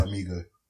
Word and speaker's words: Farmiga: John Bernthal and Vera, I don't know how to say her name. Vera Farmiga, that woman. She's been in stuff. – Yeah, that's a good stab at it. Vera Farmiga.